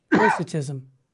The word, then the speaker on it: Docetism: Docetism. I pronounced it wrong. Thank you. Docetism.